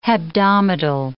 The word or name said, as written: hebdomadal